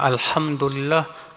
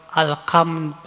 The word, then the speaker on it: alhamdulillah: Ada lagi yang harusnya alhamdulillah, alkam, pakai kaf, alhamdulillah.